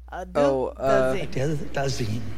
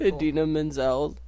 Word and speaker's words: Idina Menzel: Adele Dazeem. – Pitbull. Idina Menzel.